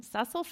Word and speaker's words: Cecil: So this hymn was written by Cecil is the female pronunciation, Cecil Francis Alexander, who lived from 1818 to 1895.